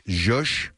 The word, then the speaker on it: zhuzh: It's zhup, there it is. – Well, it's spelled Z-H-U-Z-H. Zhush.